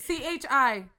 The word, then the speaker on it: Chi: Well, you know, how do you pronounce it? Because here you go. – You want to know why? – CHI. – What? – That hair brand. – The chi? – Yeah.